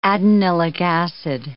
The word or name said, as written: adenylic acid